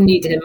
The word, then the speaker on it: Nedham: Marchamont Nedham? – Yes, or Needham.